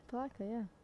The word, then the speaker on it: Palatka: That's how you pronounce it? – Palatka, yeah.